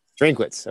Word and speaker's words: Drinkwitz: Drinkwitz.